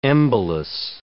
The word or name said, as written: embolus